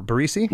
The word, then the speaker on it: Barresi: It was produced by the band and Joe Bar- Barresi. Is that how you pronounce it? Bar- Barresi. – Mm-hmm.